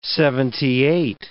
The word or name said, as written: seventy-eight